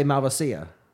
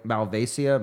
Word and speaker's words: Malvasia: Like the Italians will often say Malvasia. Like they add an extra syllable, like Malvasia, Malvasia.